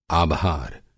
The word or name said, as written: आभार